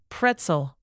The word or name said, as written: pretzel